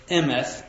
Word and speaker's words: emeth: And they say that the Hebrew word for truth is a word emeth, E-M-E-T-H. That's the transliteration from Hebrew into English. But they say that this word in Hebrew actually is a three-letter word, this word emeth that we pronounce that way, three-letter word.